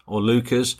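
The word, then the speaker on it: Leuchars: The name of the train station is Lucas, depending on your pronunciation.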